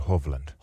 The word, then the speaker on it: Hovland: Ja sitten toinen koko kilpailun paras pelaaja, ehkä tällä hetkellä maailman paras pelaaja, Norjan Viktor Huvland. – Tästä tuli, nyt täytyy, mutta keskeytä, sori. Viktor Hovland. – Hovland. Hovland, joo. Näin onkin.